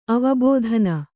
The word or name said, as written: ಅವಬೋಧನ